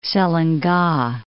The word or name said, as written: Selenga